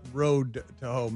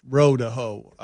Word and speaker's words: row to hoe: It's not Road to Ho. Many of you have been saying it wrong, incorrectly your entire life. – Well, I said it wrong. – Well, no, but many people in the audience thought it was Road to Ho. And it's row to hoe.